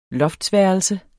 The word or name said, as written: loftsværelse